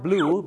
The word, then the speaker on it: blew: blue